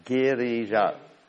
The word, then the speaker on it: Girija: Girija. Like Krishna's Giridhari. Or Giriraj, it says Girija.